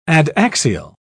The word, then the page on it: adaxial: British English